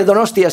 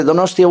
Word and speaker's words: Donostia: En primer lugar, debo decir cuán humilde y cuán sorprendido también a su vez y agradecido por recibir el se dice Donostia, sí, se dice Donostia Wood. – No, it is right, okay. – Hasta el intérprete me está diciendo que lo pronuncio bien.